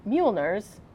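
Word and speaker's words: Mjölnir: So his lovely wife, Elsa, was doing an interview with Men's Health magazine, and she said that he has collected Mjolnirs.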